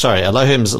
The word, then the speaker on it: Elohim: Elohim's loving grace.